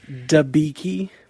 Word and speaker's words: Debicki: Debicki. don't do that. Don't do that. That's her name.